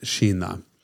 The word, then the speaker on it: Jynah: J Y N A H, pronounced Shina.